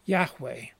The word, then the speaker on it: Yahweh: That is the meaning of the name of God, normally pronounced Yahweh.